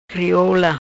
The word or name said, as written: Crioula